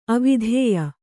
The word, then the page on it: ಅವಿದ್ಯೆ: kannada